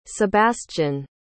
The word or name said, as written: sebastian